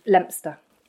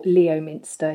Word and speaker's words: Leominster: yeah, born in a little town that nobody knows, and then all of your poddies will probably know it, Leominster, or Leominster, if you want to say it how it's spelt.